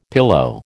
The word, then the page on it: pillow: American English